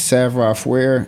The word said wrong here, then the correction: savoir faire: So if I'm not mistaken, and I believe I pronounced that totally wrong. – Oh, this is short. It's savoir faire. So it's savoir faire.